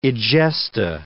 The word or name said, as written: egesta